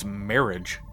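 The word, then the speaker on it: Merhige: Elias Merrig. I don't think I'm saying his name right, but I watched the trailer. Apparently, it's Marriage.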